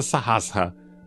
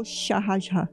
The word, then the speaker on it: Sahasra: A gente usa a pronúncia Sahasra. Mas tem pessoas, dependendo de onde você está nos Estados Unidos, que é Sahasra. – Ou Shahasra. Shahasra. Eles falam Shahasra.